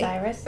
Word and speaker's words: Osiris: And sometimes they put on Anubis, the god of the mummification, but it's also known as also the god of death, like Orsis. I can't say his name correctly. Osiris. – Osiris. Thank you.